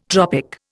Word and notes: tropic: Indian

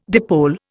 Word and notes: dipole: Indian

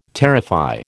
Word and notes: terrify: American English

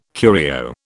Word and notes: curio: British English

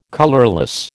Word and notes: colourless: American English

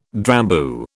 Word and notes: Drambuie: British English

